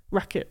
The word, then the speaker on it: RAQT: So it's spelled RAQT.